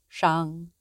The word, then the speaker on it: Shang: Shang, da da da da da, Zhou. Shang is spelt S-H-A-N-G and Zhou is Z-H-O-U.